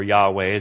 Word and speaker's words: Yahweh: They reject the idea of the supreme God, Jehovah, or Yahweh as it's probably pronounced.